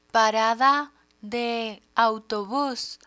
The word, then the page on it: parada de autobus: Spanish